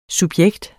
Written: subjekt

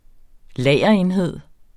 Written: lagerenhed

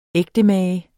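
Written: ægtemager

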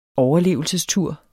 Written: overlevelsestur

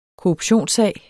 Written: korruptionssag

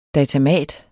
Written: datamat

